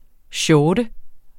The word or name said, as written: shorte